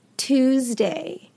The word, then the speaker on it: Tuesday: One thing I want you guys to bear in mind is that the S in Tuesday is actually pronounced with a Z sound, Z, Tuesday, Tuesday. And the U in this instance is pronounced like an ooh sound, okay? Tuesday, Tuesday.